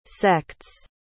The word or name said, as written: sects